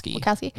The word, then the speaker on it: Wachowski: Yeah, it is interesting as well, because I guess talking about the Wachowski, is that how you say it? – Wachowski.